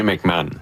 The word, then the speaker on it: McMahon: Sébastien McMahon, de l'Industrielle Alliance. – Parfait. Alors, merci de comme la prononciation. Comme l'ancien corps arrière des Bears.